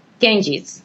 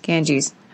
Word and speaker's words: Ganges: I'm glad we talked about the slums and Mumbai, and especially about, is it the Ganges River? Ganges? How do you say it, actually? – Ganges. – Ganges.